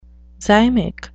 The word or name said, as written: zymic